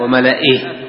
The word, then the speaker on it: وملئه: هنا الياء لا تنطق، وملئه. يوجد هناك ياء بعد الهمزة لكن لا تنطق، هذه الآن الآيات في سورة يونس.